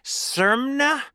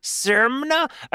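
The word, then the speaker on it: Czermna: or excuse me, it's C Z E R M N A, Sermna. Sermna, a cl- I think that sounds great.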